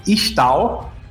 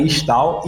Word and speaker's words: Ixtal: então, eu vou deixar aqui, eu vou falar aqui, né, que Kiana, a pronúncia corretas, né, é Kiana, a terra de onde ela veio é Istal, né, I-X-T-A-L, Istal, e a cidade dela é Ishaokan, que até o pessoal ficou brincando, ah, Ishaokan do Mortal Kombat.